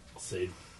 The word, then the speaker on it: Sade: Sade.